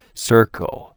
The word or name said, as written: circle